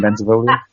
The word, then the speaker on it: Bentivoglio: Is there a G in Bentivoglio? – There is a G in Bentivoglio. – It's a silent G.